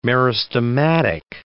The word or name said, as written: meristematic